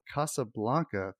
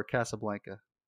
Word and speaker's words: Casablanca: It's just like, I don't put too much ass in the casa, so. Casablanca. I don't go that far with it.